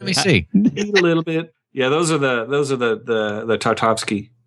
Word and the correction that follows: Tartakovsky: Tartakovsky. I've been pronouncing his name wrong the whole episode.